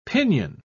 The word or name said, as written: pinion